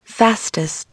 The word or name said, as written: fastest